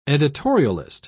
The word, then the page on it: editorialist: British English